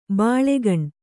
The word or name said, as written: ಬಾಲ್ಕನಿ